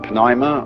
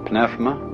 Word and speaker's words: pneuma: The Greek word, you may pronounce it pneuma or pnefma, is the same as spirit.